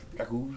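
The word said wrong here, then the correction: Jacques: It's actually Jock. Stick it. Jock. I can't remember how to pronounce it. Jock. It's French, dude.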